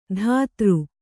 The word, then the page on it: ಧಾತೃ: dhātř